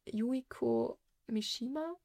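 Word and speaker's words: Yukio Mishima: Und zwar nennt sich der Roman Leben zu verkaufen von Yuiko Mishima, glaube ich. Wird es ausgesprochen? Ja.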